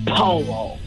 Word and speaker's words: Paulo: No, the P-A-U-L-O. Paolo. Am I saying that right, Paolo? – It's actually an L. Paolo.